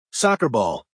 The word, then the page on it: soccer ball: American English